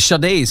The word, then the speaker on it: Sade: Det var Sadie Nadia, eller Sade ska jag väl säga att hon heter eftersom det är så man uttalar det.